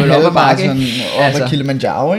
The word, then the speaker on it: Kilimanjaro: Kilimanjaro. Er det rigtigt udtalt? Ja, det er det.